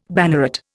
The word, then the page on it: banneret: Indian